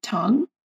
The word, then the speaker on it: Tonge: Did I say that right? Tong. Tongue, like, eh, your tongue.